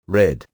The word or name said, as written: red